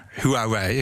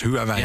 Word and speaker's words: Huawei: Ehm, wij als Huawei, ik zal het maar op zijn Nederlands uitspreken. Wij als Huawei in Nederland zijn een Nederlands bedrijf. Mm Huawei, want zo spreek ik het uit.